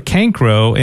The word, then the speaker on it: Cancro: is it Cancro? – Yeah, it is. – Yeah, Peter Cancro, and that, to me, seems pretty intimidating to take over from really what's a legendary figure with this franchise.